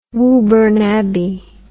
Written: Woburn Abbey

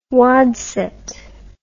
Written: wadset

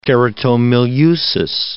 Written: keratomileusis

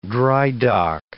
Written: dry-dock

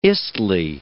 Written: istle

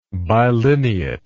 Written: bilineate

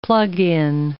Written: plug-in

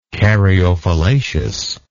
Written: caryophyllaceous

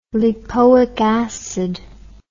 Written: lipoic acid

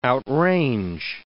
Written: outrange

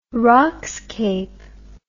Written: rockscape

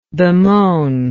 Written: bemoaningly

